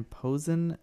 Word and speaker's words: Posehn: Brian Posehn, I think is how you say it.